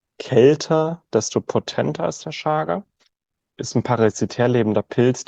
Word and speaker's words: Chaga: Chaga, auch einfach Chaga ausgesprochen, auch wenn es CH, Chaga, das kommt aus dem Sibirischen.